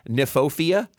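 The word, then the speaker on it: Kniphofia: It's called Red Hot Poker, Nymphophia, spelled with a silent K, you little devil.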